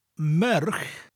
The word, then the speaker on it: merch: Merch, which is the word for woman, it sounds like the word me, followed by a ch sound.